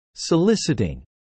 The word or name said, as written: soliciting